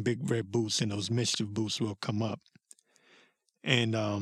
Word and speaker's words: MSCHF: If you need, it's called, I think they're called Mischief, but it's spelled M-S-C-H-F.